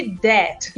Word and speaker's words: debt: É, a mesma coisa de debt, que é tipo dívida, né? – Dívida, né? Debt, mas que é debt.